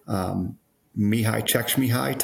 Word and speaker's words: Mihai Csikszentmihalyi: Are you talking like Mihai Csikszentmihalyi type flow?